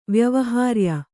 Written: ವ್ಯವಹಾರ್ಯ

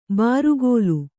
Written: ಬಾರುಗೋಲು

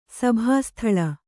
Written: ಸಭಾಸ್ಥಳ

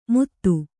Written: ಮುತ್ತು

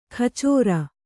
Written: ಕಚೋರ

